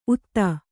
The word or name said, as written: ಊಧ